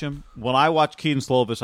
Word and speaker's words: Slovis: Slovan? – Keaton Slovis.